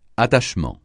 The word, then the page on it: attachement: francais